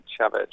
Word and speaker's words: Chavez: She said Chavez, like the Venezuelan dictator.